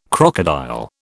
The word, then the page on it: crocodile: British English